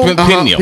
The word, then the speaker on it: Peniel: Peniel? – Peniel.